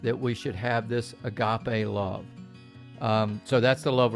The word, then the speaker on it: agape: Might be a little bit of that in what we discuss, but really we're talking about this love called agape love. It's spelled A-G-A-P-E.